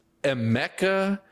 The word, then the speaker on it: Emeka: I think it's Emeka. – Emeka. I said Igbuka. – Yeah. I always say Emmanuel for whatever reason. Emeka Igbuka.